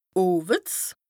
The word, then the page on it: oweds: Bas Rhin